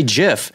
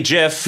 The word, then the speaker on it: GIF: That's right, because you say GIF. – That's a GIF. – Yeah. – I like a soft G.